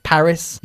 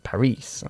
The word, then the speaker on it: Paris: Paris is pronounced differently. We put the emphasis on the first syllable in English. Paris, y no Paris, okay?